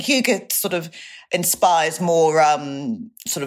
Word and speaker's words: hygge: I think that's how you pronounce it. It's spelled Hygge, but Hygge, is it? – Yes.